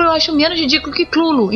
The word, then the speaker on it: Cthulhu: O mais próximo é Clulo e não Cutulo.